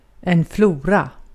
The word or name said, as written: vahşi yaşam